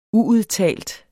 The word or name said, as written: uudtalt